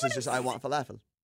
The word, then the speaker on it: falafel: Falafel. – Falafel. – What? – You don't, it's not a long A.